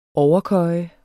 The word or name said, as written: overkøje